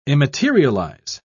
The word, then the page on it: immaterialize: British English